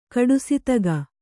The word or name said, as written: ಕಡುಸಿತಗ